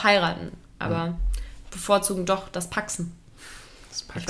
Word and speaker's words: pacsen: Ich weiß auch gar nicht, ob ich das richtig ausspreche mit dem, ob das Paxen überhaupt so. – Ja. – Aber ja. P-A-C-S-E-N. – Paxon. – Paxon. Genau.